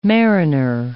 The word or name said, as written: mariner